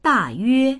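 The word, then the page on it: da4yue1: Mandarin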